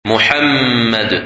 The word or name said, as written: Muhammad